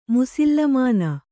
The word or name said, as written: ಮುಸುಲುಮಾನ